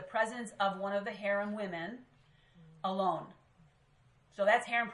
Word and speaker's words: harem: But and I'm going to say the Arabic word. This is how they would pronounce it. Harem. Anybody ever heard of the word harem in Arabic? – Yeah.